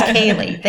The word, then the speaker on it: Ceilidh: It's Ceilidh.